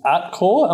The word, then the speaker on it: Atkore: The next stock that you are having a look at is Atkore. Am I saying that right? – Yeah, 100%. ATKORE.